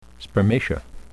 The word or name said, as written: spermatia